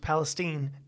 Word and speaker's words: Palestine: And yes, it's pronounced Palestine, Texas.